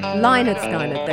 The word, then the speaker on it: Lynyrd Skynyrd: Lynyrd Skynyrd, there you go.